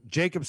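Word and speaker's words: Jaccob: I call him Jakub. Why do you got to? Who cares? Do you know who I'm talking about when I say it? You correcting me of all people. – Butchering the name. You got to get it right so people know who you're talking about.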